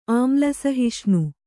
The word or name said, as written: ಆಮ್ಲಸಹಿಷ್ಣು